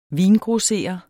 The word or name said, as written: vingrosserer